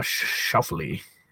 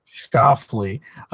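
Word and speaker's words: Schauffele: Shuffley. – Yeah. – You say his name for me. – Xander Shuffle. – Shuffley.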